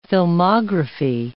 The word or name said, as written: filmography